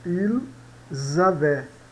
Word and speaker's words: avaient: E, por último, ils avaient. E aqui sempre lembrando, né? Nunca é pronunciado o ENT na terceira pessoa do plural.